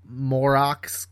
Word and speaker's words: Moroks: Which is why, like, the bad guys, the Morox guys, they rhyme with morons.